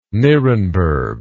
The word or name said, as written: nirenberg